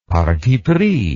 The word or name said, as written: parti pris